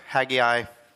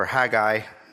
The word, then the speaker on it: Haggai: So today we're going to finish up the book Haggai, or Haggai. You could pronounce it really either way. In the Hebrew, the G is actually pronounced double, so you can get away with either one, which I like, because I like how you can get away with words that you can mispronounce.